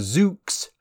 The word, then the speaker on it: Zoox: It's called the Zoox. I think that's the correct pronunciation, Z-O-O-X. Zoox, and it's a completely autonomous ride-hailing vehicle which seats 4 people in a 2x2 face-to-face configuration.